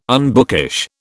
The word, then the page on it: unbookish: British English